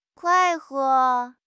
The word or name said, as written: 快活